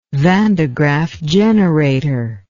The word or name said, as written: Van de Graaff generator